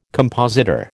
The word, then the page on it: compositor: American English